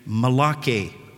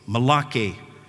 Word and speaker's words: Malachi: It's interesting to me that the Jews pronounce his name a little differently. They pronounce his name Malachi, Malachi, which literally means messenger.